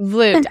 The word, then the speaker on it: Vloet: For everyone listening and watching, we're here with Sanne Vloet. Did I pronounce that right? – Vloet. I know, it's a difficult one. – Oh, Vloet. I was like doing it the French way.